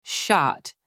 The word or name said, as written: shot